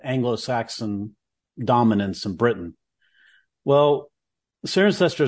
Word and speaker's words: Cirencester: Cirencester? I may not pronounce that right. – Cirencester.